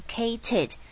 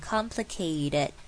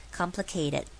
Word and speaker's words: complicated: 那请不要傻傻的直接念 kated，这样不好听也太刻意了，我们最自然的方法就是把它念成像 d 的声音，就变成 complicated complicated，就会很好听。